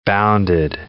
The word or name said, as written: bounded